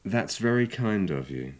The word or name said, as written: thats very kind of you